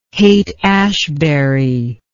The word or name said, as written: Haight-Ashbury